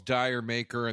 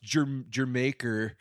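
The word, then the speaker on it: D'yer Mak'er: Everybody calls Dire Maker, and then the hardcore fans go, you know, it's pronounced Jermaker.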